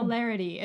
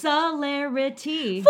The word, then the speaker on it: celerity: It sounds like celery, but... – And celebrity, but and it's somehow... – celerity. It's like... – better than both. – Very... just good. – It's celerity. – Four syllables!